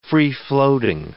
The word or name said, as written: free-floating